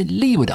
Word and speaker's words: leeward: They're known as the Leeward Islands because they're on the lee side, away from the wind. All right, if you're a sailor, you call it leeward, even if you still spell it L-E-E-W-A-R-D.